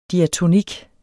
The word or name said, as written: diatonik